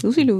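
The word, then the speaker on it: Lucy Liu: Ähm, spricht man ja eigentlich Lucy Lou? Ja, ne? – Lucy Lou, ja. Lucy ja.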